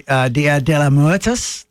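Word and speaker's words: Día de los Muertos: Talking about Halloween and obviously Dia de los Muertos that comes over.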